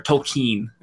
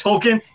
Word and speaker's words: Tolkien: Tolkien saying that or Tolkien, I should say, to say it properly. – No, it's Tolkien. He doesn't know how to pronounce his own name.